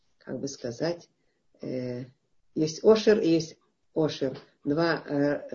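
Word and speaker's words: Ошер (עושר): А одно слово э, Ошер, которое начинается с Сайном, Ошер, а звучит вроде бы одинаково. Э, вроде бы, немножко есть оттенок э, в гортанности э, звука э, ай, но сегодня не, не произносится гортанность у него.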